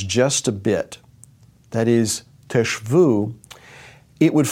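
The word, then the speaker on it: teshvu (תשבו): Now, that phrase, you shall dwell, is translated from the Hebrew word teshvu. Teshvu, you shall dwell. It's spelled tav shin bet vav, teshvu.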